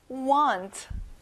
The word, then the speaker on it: want: 자, A지만 소리가 나요. want.